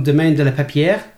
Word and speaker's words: Domaine de la Pepierre: So it's called Le Pepi, and it's from Domaine de la Pepierre. Mm-hmm. I pronounced that correctly. – Yeah, I think you got it.